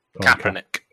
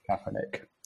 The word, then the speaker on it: Kaepernick: Is it Kaepernick? Is that how you pronounce his name? – Yep, Kaepernick. – Kaepernick.